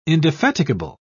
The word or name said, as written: indefatigable